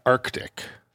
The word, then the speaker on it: Arctic: I always pronounce the first C in Arctic. – That's correct.